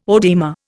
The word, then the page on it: oedema: Indian